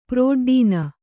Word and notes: ಪ್ರೋಡ್ಡೀನ: Kannada

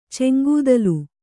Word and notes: ಚೆಂಗೂದಲು: Kannada

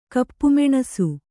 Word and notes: ಕಪ್ಪುಮೆಣಸು: Kannada

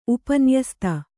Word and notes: ಉಪನ್ಯಸ್ತ: kannada

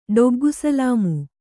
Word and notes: ಡೊಗ್ಗುಸಲಾಮು: ḍoggu salāmu

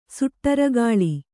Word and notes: ಸುಟ್ಟುರೆಗಾಳಿ: suṭṭure gāḷi

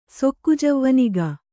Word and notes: ಸೊಕ್ಕುಜವ್ವನಿಗ: kannada

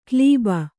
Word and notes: ಕ್ಲೀಬ: klība

